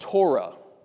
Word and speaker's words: Torah: All right, the Hebrew word for law in the Bible is pronounced Torah.